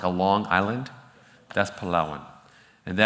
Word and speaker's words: Palawan: Everyone say Palawan. Say it like that though. Palawan.